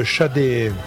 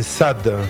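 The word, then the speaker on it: Sade: Alors, c'est un disque de Shadé, ou bien Sad, suivant la prononciation que l'on veut le donner.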